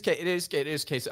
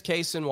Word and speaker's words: Cason: It is Cason. I didn't realize he went 10. I thought he went 11. Yeah, it is Cason Wallace. That's how I pronounced it. That's how I've heard it.